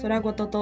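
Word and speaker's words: 宙ごと: 早速最初のコーナー空ごとに参りたいと思います宇宙の宙に言葉のことと書いて空ごとと読みます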